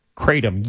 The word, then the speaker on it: Kratom: And now this thing called, am I pronouncing it right? Kratom? – Kratom, yes, I believe so.